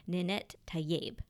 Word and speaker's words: Ninet Tayeb: played by Ninet Tayeb. I hope I'm saying that right.